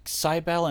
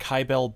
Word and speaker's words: Cybele: I've heard it Cybele and Kybele both. I think Kybele would be how you would pronounce it with a Japanese tongue.